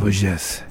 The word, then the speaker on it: Vosges: Called Vosges. I don't know how you pronounce it. – Vosges.